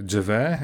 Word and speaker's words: Gervais: Dżewe, jak czytałem, tak się wymawia jego nazwisko. – Gervais, Gervais, Ricky Gervais? Dżewe, Dżewe, bez R, R jest bezdźwięczny, wiesz, Dżewe. Dobra.